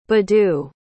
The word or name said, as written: badu